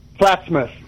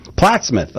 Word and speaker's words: Plattsmouth: Plattsmouth. – Plattsmouth, okay, yeah.